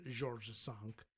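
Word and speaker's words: George V: We were reading about Kimberly Guilfoyle hanging out at the what is now known as the Four Seasons, Georges Sank. And yeah, I believe that's the actual pronunciation only because I saw it in a movie.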